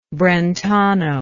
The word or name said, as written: brentano